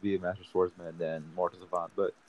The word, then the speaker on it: Mortal Savant: I want to say Mercy Servants, if I say his name right? – Mortal Savant. – Mortal Savant? – Yeah.